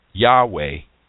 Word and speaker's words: Yahweh: of the covenant name God, which is pronounced Yahweh in the Old Testament.